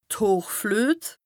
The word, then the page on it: d' Hochfluet: Bas-Rhin; Strasbourg